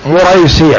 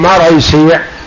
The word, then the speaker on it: مريسيع: بالضم فالفتح مصغرة، يعني هذه قراءتها بالضم الميم، مريسيع مهم مريسيع مريسيع، ثم الراء مفتوحة مر بالضم فالفتح مصغرة، يعني نطقها نطق المصغر، مريسيع مثل قنيديل.